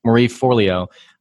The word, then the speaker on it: Marie Forleo: And Maria Forleo, is that how you say her name? – Marie Forleo.